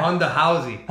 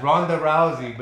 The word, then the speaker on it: Ronda Rousey: They had a joke because her name is Ronda, but the Brazilians pronounce it Honda. – Honda, yeah. – Honda Housey. – Honda Housey. – Honda Housey. And they said, oh, Honda Housey, like haunted house. But it's not. It's Ronda Rousey, but they made a joke about it.